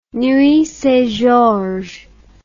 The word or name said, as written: Nuits-Saint-Georges